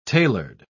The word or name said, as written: tailored